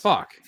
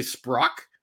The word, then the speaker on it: Spock: I'm like, did I say Sprock? – All right.